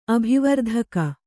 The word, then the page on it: ಅಭಿವರ್ಧಕ: abhivardhaka